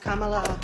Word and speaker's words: Kamala: It's pronounced Kamala. It rhymes with Kamala. It ain't Camila Cabello.